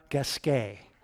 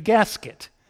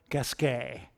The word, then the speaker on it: Gasquet: I said, it's spelled just like racket, Q-U-E-T. It rhymes with racket. It must be Richard Gasquet. She thinks I'm clueless. She says, his name is Richard Gasquet.